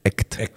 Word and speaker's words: ECHT!: Attention, je me trompe toujours en prononciation, c'est ECHT. Donc E-C-H-T, point d'exclamation, qui est un groupe belgique, qui est vraiment pour le coup entre.